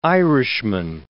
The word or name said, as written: Irishman